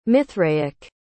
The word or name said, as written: mithraic